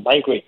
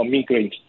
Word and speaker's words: migraine: Then the other common type of headache conditions that we often see are things we call migraines or migraines, depending on whether you use American or the British pronunciation.